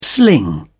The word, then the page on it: sling: British English